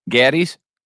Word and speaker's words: Gatti's: Gatti's? – Yeah, someone told me that the A is like a short A, like Apple, Gatti's.